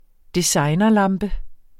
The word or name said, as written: designerlampe